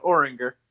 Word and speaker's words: Oringher: Uh, Oringher.